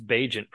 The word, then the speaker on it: Bagent: which is backup undrafted quarterback Tyson Bagent, not Bagent, it's Bagent pronounced, by the way.